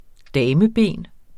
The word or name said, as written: dameben